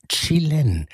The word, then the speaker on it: Jalen: Und ich habe am Anfang immer gesagt, Jalen Ngonda, nein, Chilen. So wird er richtig ausgesprochen.